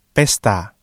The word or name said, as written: pesta